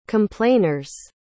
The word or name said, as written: complainers